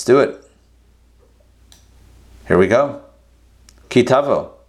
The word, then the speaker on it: Ki Tavo: Torah portion this week is Ki Tavo or Ki Savo, depending on how you want to pronounce it. I grew up saying Ki Savo, but, you know, the Israeli way and the modern way of saying it is Ki Tavo. So, you know, all us Ashkenazic pronunciations can go out the window.